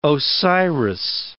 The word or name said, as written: Osiris